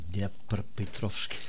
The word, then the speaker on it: Dnepropetrovsk: In Kharkov en in Dnepropetrovsk.